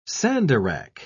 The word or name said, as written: sandarac